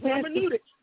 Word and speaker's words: Hermeneutics: I'm trying to say it again. Hermeneutics. How you say it? – Hermeneutics.